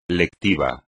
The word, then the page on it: lectivo: espagnol